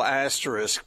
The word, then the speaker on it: asterisk: Well, I certainly think no asterisks is, did I say that right? – Asterisk. – Isk.